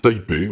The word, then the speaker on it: Taipei: Et puis, notre cher Lee Jin Lee joue donc cette musique au National Concert Hall de Taipei. Je sais pas comment ça se prononce, mais voilà. Voilà, c'était ma réponse. À plus, bye bye. – Taipei, oui, c'est ça, oui.